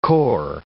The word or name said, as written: cor